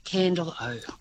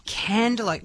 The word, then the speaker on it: Candelo: How do I say C-A-N-D-E-L-O? – It is pronounced Candle-O. – Candle-O.